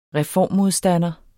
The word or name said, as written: reformmodstander